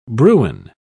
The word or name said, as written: bruin